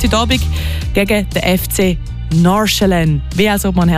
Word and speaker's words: Nordsjælland: Also eigentlich ist es gar nicht so eine Sache, aber man würde es so aussprechen. Nachschillen. Das D sage ich eigentlich nicht und das andere ist so Schä. Nachschillen. Ich hoffe, das hilft.